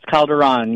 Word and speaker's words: Calderon: It's Calderon. You said it right. Calderon.